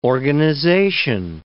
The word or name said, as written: organization